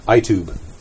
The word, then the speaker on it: Itube: I-T-U-B-E. Itube.